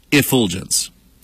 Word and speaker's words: effulgence: Infulgence. I'm sorry, effulgence.